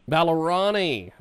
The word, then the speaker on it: Vallorani: Vallorani? – Close, but as they say, no cigar. It's Vallorani. – Vallorani.